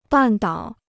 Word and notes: 半岛: bàn dǎo

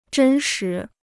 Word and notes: 真实: zhēn shí

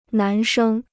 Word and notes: 男生: nán shēng

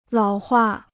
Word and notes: 老化: lǎo huà

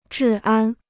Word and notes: 治安: zhì ān

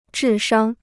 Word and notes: 智商: zhì shāng

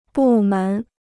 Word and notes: 部门: bù mén

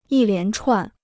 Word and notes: 一连串: yī lián chuàn

